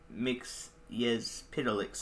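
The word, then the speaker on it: Mxyzptlk: I am Mix Yez Pitalik. So the same name, but it's actually spelled like how you say it.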